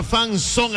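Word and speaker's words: zone: Se escribe fan, F-A-N. Z-O-N-E, se pronuncia son, fan son, escríbelo así, fan sone al WhatsApp Olímpica, escríbete con todos los datos personales y gana entradas dobles para que asistas al malecón.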